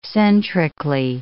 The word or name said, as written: centrically